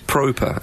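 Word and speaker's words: Pröpper: But they've signed the Dutchman, Davy, I think it's Proper, is how you pronounce it, but we will be calling him Proper.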